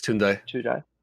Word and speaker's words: Tunde: I don't know how to say the lead singer's name. Is it Tunde? – It's Tunde.